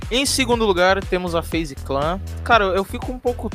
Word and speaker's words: FaZe Clan: Mas temos, eu falei certo, FaZe Clan.